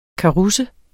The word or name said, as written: karusse